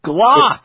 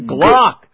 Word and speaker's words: Glock: It's pronounced. Glock. – That's what I said. Glock. Glock.